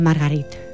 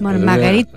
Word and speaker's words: Marguerite: Eh, Marguerite, tú lo pronuncias mejor, lógicamente que estuviste en Montpellier.